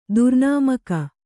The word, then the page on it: ದುರ್ನಾಮಕ: kannada